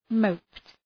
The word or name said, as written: moped